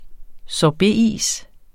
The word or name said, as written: sorbetis